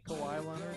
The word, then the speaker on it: Kawhi Leonard: Shout out to Kawhi Leonard. Did I say his name right? – Kawhi Leonard.